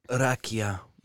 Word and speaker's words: rakia: Or as we like to call it, Rakia. That's how you say it. – Arakia. – Yeah. No, Ra.